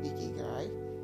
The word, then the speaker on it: Ikigai: Okay, Ikigai, pronounced Ikigai, is defined as a Japanese concept that means a reason for being.